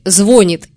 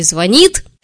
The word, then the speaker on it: звонит: Про русский язык сегодня поговорим, довольно неожиданно, но ударение в слове «звонит» или «звонит» изменится. Сейчас ударение правильно ставить на второй слог.